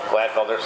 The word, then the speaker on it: Glatfelter: Glatfelter, sorry, I butchered that one.